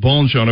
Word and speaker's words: Bonchon: Okay, with my Alabama dialect and all that, I want to make sure I'm saying your name correctly and Bonchon.